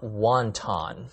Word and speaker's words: wonton: You must have looked up wanton, W-A-N-T-O-N. – Wanton, not wonton. – That's right. It's pronounced wanton. That's a different word which means immoral and cruel. The wonton mentioned in the lesson is spelled W-O-N-T-O-N. – Oh, I see. 混沌英文叫做 wonton, W-O-N-T-O-N. 有另外一个字Wanton, W-A-N-T-O-N, 那是一个形容词，指的是放纵的、残暴的，两个字不要混淆。You know, I'm curious, are there any words in English that have a Chinese origin? – Actually, there are plenty of them.